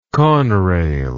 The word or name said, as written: conrail